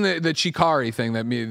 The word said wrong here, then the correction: Chiaki: Chiaki. Chiaki is that how you pronounce it? Uh, okay, Chiaki.